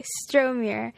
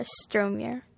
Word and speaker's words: Stromuhr: Okay, Stromer. S-T-R-O-M-U-H-R, Stromer.